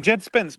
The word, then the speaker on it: Jed Spence: I would say Jared Spence was man of the match, man. – Oh, 100%. Yeah, you're saying his name wrong, by the way. You're saying Gerard Spence. Jed Spence.